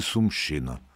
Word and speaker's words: Сумщина: Дві є області, в яких місцеві люди ставлять наголос на «щі» – Харківщина і Сумщина.